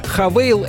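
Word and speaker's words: Haval: «Хавейл» — это китайская марка, их крупнейший производитель внедорожников. Пишется как «Хавал».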